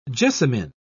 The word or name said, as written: Jessamine